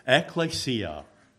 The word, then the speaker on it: ekklesia: The Greek ekklesia, people say it all differently. I'm just going by what it's pronounced as here. Ekklesia. It literally is ek and kaleo, called out from.